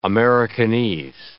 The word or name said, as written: Americanese